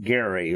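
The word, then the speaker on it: Gerry: So, Gary is how right. – he pronounced his name.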